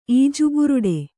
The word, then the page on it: ಈಜುಬುರುಡೆ: Kannada